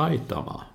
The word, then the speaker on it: Aitama: Men Jonas Aitama, han sa det är så enkelt, det uttalas Aitama.